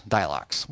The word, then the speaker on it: Dylox: Well, the plus is a chemical called Dilox. After the last sermon, they weren't asking me spiritual questions about my sermon. They were wanting to know about Dilox. How do you spell that? D-Y-L-O-X, Dilox.